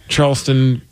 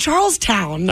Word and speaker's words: Charleston: Charleston. – You said Charlestown.